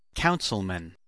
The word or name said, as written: councilman